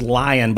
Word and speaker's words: LAION: There's a new one called Lion. It's LAION.AI. It's open source artificial intelligence. – What did you say it was called? – It's pronounced Lion, but it's LAION dot AI.